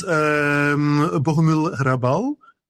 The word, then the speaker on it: Bohumil Hrabal: Well, for instance, Bohumil Hrabal. I like a lot. Do I pronounce it correctly? Or? – Very good pronunciation.